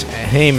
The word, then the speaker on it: Haim: Yeah, and I guess Haim is really spelt, or it's pronounced Haim. – Haim? – Okay. Yeah, I don't know. It's supposed to rhyme with time.